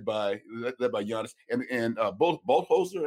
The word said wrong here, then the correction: Budenholzer: Am I pronouncing his name yeah, right? – Mike Budenholzer.